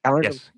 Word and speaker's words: Calendly: And they schedule a time Calendly. How do you say that? – Calendly? Yes. Yeah. Calendly. Yeah.